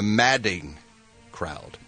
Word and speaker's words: madding: I've been mispronouncing it all morning long. I've been saying maddening crowd. But it's not that. It's far from the madding crowd.